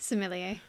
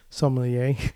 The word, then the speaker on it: sommelier: Sommelier. – Sommelier, which obviously plays a lot of factors on the sense of smell.